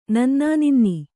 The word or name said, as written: ನನ್ನಾನಿನ್ನಿ